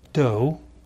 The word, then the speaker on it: Doe: Now, my last name is Doe, D-O-E, and all my life long I've had jokes about John Doe and all kinds of things.